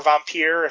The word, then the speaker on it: Vampyr: Now, the thing about Vampire is, or Vampyr, if you want to do it with the Nosferatu pronunciation, is that I actually preferred Call of Cthulhu over Vampyr.